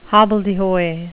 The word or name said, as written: hobbledehoy